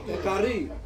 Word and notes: kari: Ansus

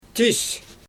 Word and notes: tiws: Xyzyl

